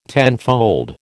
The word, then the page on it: tenfold: American English